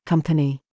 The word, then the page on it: COOK: British English